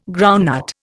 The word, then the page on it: groundnut: Indian